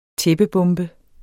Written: tæppebombe

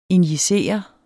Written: injicere

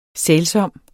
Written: sælsom

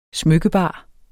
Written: smykkebar

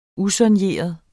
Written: usoigneret

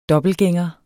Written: dobbeltgænger

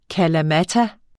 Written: kalamata

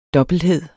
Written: dobbelthed

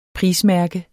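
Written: PRISMÆRKE

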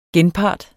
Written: genpart

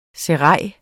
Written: serail

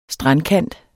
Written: strandkant

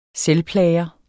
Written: selvplager

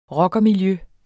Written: ROCKERE

